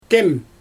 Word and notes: tem: Xyzyl

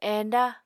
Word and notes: enda: Apatani

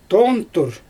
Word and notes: toontur: Xyzyl

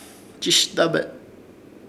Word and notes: Chɨshdabe: Ishir Chamacoco

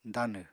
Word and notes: danw: apatani

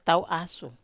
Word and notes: tau asu: Wamesa

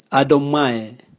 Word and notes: adomwae: Raga

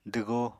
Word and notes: dwgoo: apatani

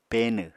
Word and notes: peenw: Apatani

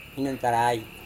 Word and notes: hinantarai: Serewen

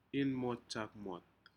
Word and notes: inmotjukmot: Aneityum

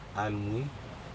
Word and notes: alMui: Aneityum

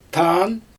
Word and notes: taan: Xyzyl

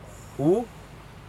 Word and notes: u: Aneityum

